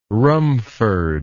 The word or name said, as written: rumford